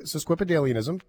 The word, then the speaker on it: Sesquipedalianism: SES is right. I was looking at the pronunciation, uh, the, the phonetic spelling. Okay, you're right. – Okay, you go. Okay, Sesquip- okay. Sesquipedalianism.